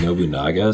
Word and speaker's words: Nobunaga: A game store guy was like, "Oh, you're buying Nobunaga?" So I'm like, "That's not how you say it." And then I looked at him like, "Oh, he's right."